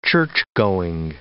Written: churchgoing

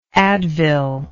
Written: advil

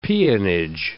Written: peonage

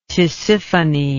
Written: Tisiphone

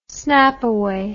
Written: snapaway

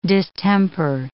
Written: distemper

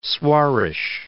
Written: Soares

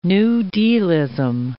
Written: New Dealism

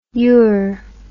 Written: ure

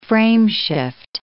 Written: frameshift

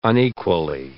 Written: unequally

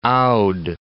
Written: Oudh

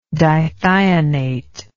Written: dithionate

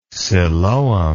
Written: siloam

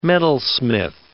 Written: metalsmith

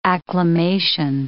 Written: acclimation

